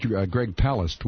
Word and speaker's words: Palast: Well, I'll tell you that, as his name is actually pronounced, Greg Palast will be my guest at 1 o'clock tonight Eastern Time.